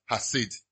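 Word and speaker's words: hasid (חסד): Hasid is spelled chased, but it's pronounced hasid.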